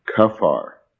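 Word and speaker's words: kaphar: In the Old Testament, that word is kafar. If you want to spell that, that's K-A-P-H-A-R. Kafar.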